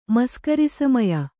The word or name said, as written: ಮಸ್ಕರಿಸಮಯ